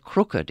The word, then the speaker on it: crooked: The word crooked is pronounced as crook-ed, the emphasis being placed upon ed in the world.